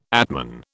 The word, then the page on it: atman: British English